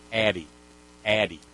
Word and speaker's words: Addyi: Oh, we get the pronouncer in paragraph 7. Thanks, New York Times. Addie.